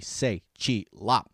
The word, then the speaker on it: Tse Chi Lop: This week, guys, we got Tsi Chi Lop. Yeah, bet you never heard of him. It's spelled T-S-E-C-H-I-L-O-P, Tsi Chi Lop.